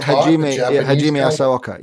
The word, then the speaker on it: Hajime Asaoka: But am I saying his name right? Asiaki, you know, the guy who's self-taught. – Yeah, Hajime Asaoka.